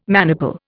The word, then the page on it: maniple: Indian